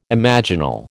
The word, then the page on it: imaginal: American English